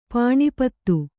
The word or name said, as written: ಪಾಣಿಪತ್ತು